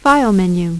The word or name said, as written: File Menu